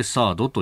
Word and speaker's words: THAAD: えー、サードターミナルハイアティテュードエリアディフェンスミサイルの略頭文字を取ると THAAD となってサードと読むということでありますこれね、配備そのものはずいぶん前から。